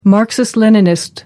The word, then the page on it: marxist leninist: American English